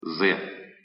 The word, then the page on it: з: Russian